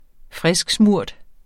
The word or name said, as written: frisksmurt